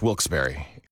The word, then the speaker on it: Wilkes-Barre: Now, I had heard that it was supposed to be pronounced Wilkes-Barre. – Wilkes-Barre Scranton's 97.9X. The only way to pronounce Wilkes-Barre.